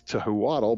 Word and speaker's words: Tehuatl: It's spelled T-E-H-U-A-T-L, which would probably make you think it's Tehuatl, but it's not. It's Tehuatl.